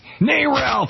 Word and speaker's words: NARAL: NARAL!